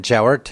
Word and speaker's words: Jawort: My name is Adrian Jowart, and that is spelled J-A-W-O-R-T, and I represent Montana Native Vote.